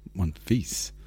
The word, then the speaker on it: Monfils: M-O-N. – Hang on, slow down. Okay. – Monteith? – Yes. Monfils. – Monfils. Oh. – I think Mon. Yeah, that's how you say it. – Okay. – Definitely not how it's spelled, though. – Oh, M-O-N-T-H. – Okay. M-O-N-F-I-L-S.